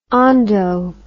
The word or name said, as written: ondo